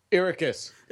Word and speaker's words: Arrakis: Would you like to say how it's said? It sounds... – Arrakis. – There you go.